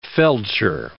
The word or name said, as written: feldsher